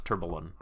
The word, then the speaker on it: tourbillon: And I think it's actually pronounced tourbillon. – Oh, tourbillon. – Tourbillon. – That sounds better. I like that one.